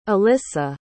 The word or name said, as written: ilyssa